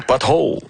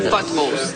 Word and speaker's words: potholes: Oui, « potholes ». – Bravo, « potholes », mais il faut bien prononcer le « p ».